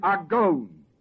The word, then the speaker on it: agon: It is the Greek word "agon," spelled A-G-O, long O.